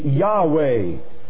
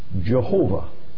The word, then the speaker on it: Jehovah: And then he said, if I were reading that as a word, I would read it Jehovah.